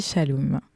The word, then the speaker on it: Xalum: XR apostrophe L-U-M, ouais, c'est ça. – Ça. – Ouais. Merci. – Exactement. Ça s'écrit Xalum, mais ça se dit Shalom.